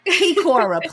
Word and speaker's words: Kikora: Kikora, please correct me because yes, now we got that.